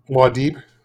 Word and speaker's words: Muad'Dib: How do you say it? – Muad'Dib. – Yeah. Muad'Dib.